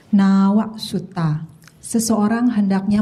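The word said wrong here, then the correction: Nāvā Sutta: Di, diulangin dulu, bukan nawa. Kalau a berarti pendek. Ini bacanya a-nya panjang. Nawa Suta. Oke. – Okay. Nawa Suta.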